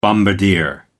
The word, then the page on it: bombardier: American English